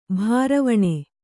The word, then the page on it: ಭಾರವಣೆ: bhāravaṇe